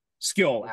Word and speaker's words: Skoll: S-K-O-L. – Okay, yep. Yeah. – S-K-O-L-L. Like Hati and Skill. Skill actually would be the pronunciation.